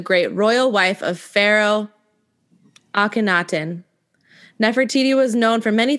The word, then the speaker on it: Akhenaten: It's Aten and it's Akhenaten. – It just says A-T-E-N.